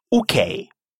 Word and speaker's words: okej: Обратите внимание, что в шведском языке первая буква читается именно как «у», а не как мы привыкли «о». Okay.